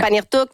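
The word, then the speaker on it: Pangnirtung: Elle fait ses capsules en direct de Panirtuk. Est-ce que c'est comme ça qu'on prononce? – Oui. – OK, ouais. Panirtuk.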